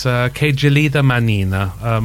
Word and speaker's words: Che gelida manina: We're going to leave this now with a little bit of Luciano Pavarotti, and I've been schooled in how to pronounce this correctly, and it's Che Gelida Manina.